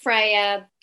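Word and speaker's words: FREIA: So to our listeners, Freya is spelled FR:EIA. – Yeah. Absolutely. – So it's pronounced Freya, spelled a bit differently.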